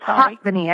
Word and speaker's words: Hatvany: It's Hatvany. – I tell everybody Hatvany. – Yeah, it's fast. And it rhymes with hot fanning. That's how I make people remember it.